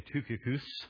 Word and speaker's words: Tychicus: Tychicus. Could pronounce it some different ways. In the Greek, we'd probably say Tychicus, but call it what you will,